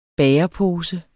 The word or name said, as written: bagerpose